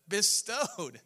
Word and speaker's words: bestowed: How do I say that? Dispode? No, that's not how you say it. Bestowed.